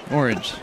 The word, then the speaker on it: orange: Orange. – Orange. That sounds like two syllables to me. – Yeah, I believe it is.